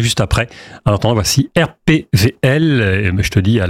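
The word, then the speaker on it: RPWL: Ça s'écrit W, mais on dit RPVL. – Ah oui, parce qu'en allemand, voilà. Le W, on ne le prononce pas, voilà.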